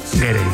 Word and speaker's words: Gere: Estaba pensando yo que si te apellidas Ger, bueno, ¿cómo se dice Ger o Gerde? – Gere. Me ha hecho gracia porque has dicho Richard Gere y luego el hijo era Gere.